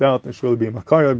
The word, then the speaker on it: מכירי: which is pretty clear that they're reading it Makire, not Makari. So that is mashma, the way to pronounce it would be Makire, not Makare.